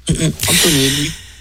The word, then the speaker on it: Antonelli: Ah voilà, Antonelli. Un peu à l'italienne, en fait, finalement, hein, comme on prononcera en italien. Antonelli, voilà.